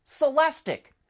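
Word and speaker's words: Celestiq: As you probably know, all of Cadillac's new EVs end in IQ, which is supposed to be pronounced "Ick," "Lyric," "Celestic."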